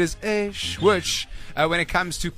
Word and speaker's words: eish: Pronounced, or rather spelt first, E-I-S-H. Pronounced A-Y dash. S-H, like shh, like shh. You're shushing a child, or you shh in a library.